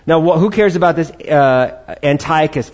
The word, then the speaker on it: Antiochus: I pronounced it my way last week. Now let me try to pronounce it the correct way this week. I said Antiochus. I'm going to repronounce that right now, because you have so much grace for me. I'm going to say Antiochus this time. All right, Antiochus, all right? It's all about which syllable you accent, all right?